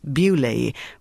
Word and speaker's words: Beaulieu: Beaulieu, pronounced Beaulieu, was a sprawling estate in the New Forest near the south coast.